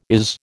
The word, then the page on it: is: American English